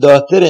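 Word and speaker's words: daughter: داتر انگلیسی هم اچ که معنای ه داتر میده هست منتها تلفظ نمیشه داتر میخونن یعنی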